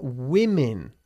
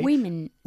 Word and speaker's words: women: We're women. Okay. – Women. – Una mujer is a woman. Pero al convertirse al plural, cambia la pronunciación de la O. En vez de decir woman, decimos women.